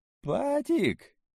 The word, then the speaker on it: Патик: «Патик?» — переспрашивает уважаемый, делая ударение на первой гласной.